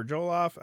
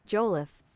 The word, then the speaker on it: jollof: Her recipe for jollof, a popular, I might be saying that wrong.